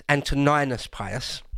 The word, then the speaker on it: Antoninus Pius: I sometimes pronounce that wrong. It's Antoninus Pius.